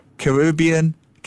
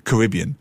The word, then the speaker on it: Caribbean: Caribbean.